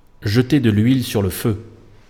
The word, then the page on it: jeter de l'huile sur le feu: Île-de-France, France; /ʒə.te də l‿ɥil syʁ lə fø/